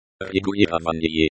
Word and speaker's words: Регулирование: Регулирование. – Регулирование, regulation, the act of regulating. This is a noun. The stress is on the third syllable.